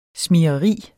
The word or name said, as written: smigreri